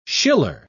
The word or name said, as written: Schiller